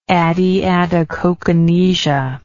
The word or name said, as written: adiadochokinesia